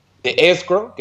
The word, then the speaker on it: escrow: Si son 30 años, por los 30 años va a tener que ver con esta cuenta de, escro, que es como se le llama. Se escribe E-S-C-R-O-W, hay una W al final.